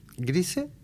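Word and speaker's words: Grisey: Grise, cuyo nombre se escribe Grisei con I griega al final, nació en Belfort en 1946, en Francia.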